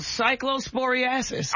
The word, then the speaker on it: cyclosporiasis: Yeah, but it's called cyclosporiasis.